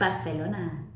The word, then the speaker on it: Barcelona: Can you pronounce Barcelona with the Castilian S? – Barcelona. – There you go. – Yes, thank you.